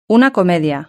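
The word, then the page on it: una comedia: Spanish (Spain)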